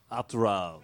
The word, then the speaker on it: Hot Rod: The weirdest thing was that he pronounces his name Hot Rod, like, do your perfect French impression. – Hot Rod.